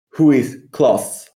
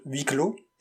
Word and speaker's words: Huis Clos: Hi, French pronunciation police over here. We've been called for a couple of infractions. The play, which was adapted to a film, is by Jean-Paul Sartre, and it's called Huis Clos.